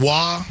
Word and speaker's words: Roy: Yeah, R-O-Y, but it's pronounced Raw.